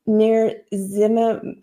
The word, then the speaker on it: nirsevimab: She announced that nirsevimab, I can't pronounce it. Can you read that? – Nirsevimab. – Thank you.